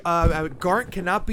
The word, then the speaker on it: Garnt: Garnt.